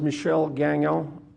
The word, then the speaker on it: Michel Gagnon: Okay, my uh, legal name is Michel Gagnon, M I C H E L G A G N O N, but I've, I've gone the name Mike.